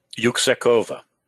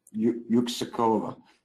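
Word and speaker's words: Yüksekova: Am I pronouncing the name right? – Yuksekova. – Yuksekova. I lived in a Turkish commando camp for 6 months, and that's the best pronunciation I got. So we were pronouncing it wrong, but we lived there during the first Gulf War.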